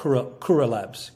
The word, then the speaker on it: Kura: So your company, I don't know how to pronounce it. K-U-R-A, how do you say that? – Oh, so it's a nonprofit I started called Kura Labs.